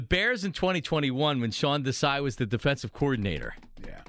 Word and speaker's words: Desai: So Sean Desai rhymes with I hope he's not high.